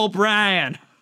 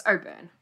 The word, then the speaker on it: O'Byrne: I, am just Americanized and I'm like, Anna O'Brien. no. – It's yeah, it's O'Byrne.